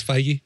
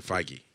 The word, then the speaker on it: Feige: Say it is Feige. – They pronounce it Feige.